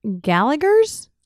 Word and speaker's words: Gallagher's: Gallagher's? – Yes.